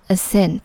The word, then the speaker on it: ascent: 注意这两个单词的重音都是在 cent 之前，读作 descent 和 ascent。